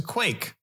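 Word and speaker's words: quaich: First, what's a quake? A quake, sort of pronounced like quake. It's Q-U-A-I-C-H. Quake. If you're familiar with the Gaelic. So if you're thinking about loch, which is lake, quech is a two-handled drinking cup or bowl.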